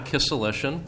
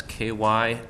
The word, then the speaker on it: Kisselyshyn: My name is John Kisselyshyn, and that's Kisselyshyn. And yes, there are no vowels in there, just the letter Y.